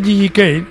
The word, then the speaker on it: JJ Cale: Bueno, pues este Gigi Cale, que se escribe JJ Cale,